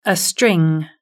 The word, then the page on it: a string: British English